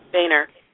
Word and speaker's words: Boehner: I'm not sure you say his name, B-O-E-H-N-E-R? – Boehner.